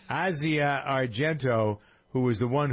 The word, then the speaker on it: Asia: It looks like Asia, but it's Asia.